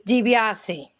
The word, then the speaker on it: De Biase: It's DiBiase.